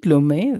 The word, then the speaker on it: Lumet: Is it Lumet? Is that how you pronounce it? – Lumet, Sidney Lumet. – Okay, Sidney Lumet.